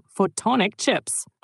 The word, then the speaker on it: Photonic: Ah, du meinst Photonic Chips, also mit Pfaff am Anfang, wie Photon, das Lichtteilchen. Also richtig ausgesprochen, Photonic Chips.